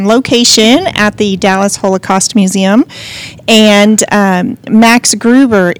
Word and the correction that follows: Glauben: is with us today. Oh, I butchered his name. – Max Globen. – Globen. – G-L-A-U-B-E-N. – Okay. I am so sorry. – Believe. It's believe.